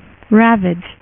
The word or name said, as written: ravage 2099